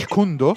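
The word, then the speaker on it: Jeet Kune Do: Jet Kunida. Isso, exatamente. Jet Kundo. Jet Kundo. a pronúncia é diferente.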